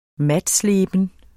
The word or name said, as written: matsleben